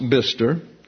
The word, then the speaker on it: Bicester: For example, you have what would appear to be Worcester, W-R-C-E-S-T-E-R, and it's pronounced Worcester. And you have Bicester, which is pronounced Bister.